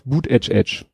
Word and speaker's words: Buttigieg: Chencher spricht man doch Boot Edge Edge. Das ist eine Anspielung auf diesen Buttigieg, den wir ja ah.